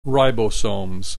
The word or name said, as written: ribosomes